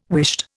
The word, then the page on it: whisht: Indian